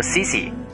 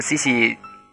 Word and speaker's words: Sissi: 我用的开始是，基本上是第一个吧，在我节目里面出现的，名字叫做 S I S S I，呃，好像是读音应该是读作 C C，可能是，如果没记错，应该是 C C 公主的 C C。呃，我我英语一向不太好，所以如果要读错的话，请大家见谅哈。呃，读了这样一个叫做 C C 朋友的留言，将它用来做成开场的文字。